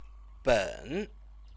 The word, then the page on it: ben: Chinese